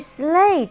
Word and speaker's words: sleigh: 所以 sleigh 这个字呢，g h 是 silent word，不发音。 sleigh 雪橇。